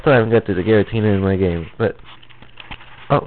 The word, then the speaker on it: Giratina: And, you know, they could have didn't say this right, but it's Giratina. Not Garita. No, Giratina.